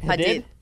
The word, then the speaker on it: Hadid: Hadid? – Yeah.